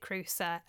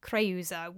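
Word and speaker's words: Creusa: In the UK, we pronounce it Krusa. Kreusa would really be a more accurate pronunciation, but UK English, we tend to say Krusa.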